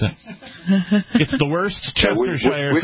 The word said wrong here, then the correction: Worcestershire: sauce. – Worcestershire. – Yeah, Worcestershire sauce.